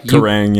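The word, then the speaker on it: Kerrang: Krang, is that how you pronounce it? Krang? They're like that. – Krang, yeah.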